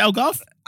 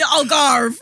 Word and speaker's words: Algarve: How do you say Algarve? – Algarve. It'll be in the Algarve. – It's the Algarve.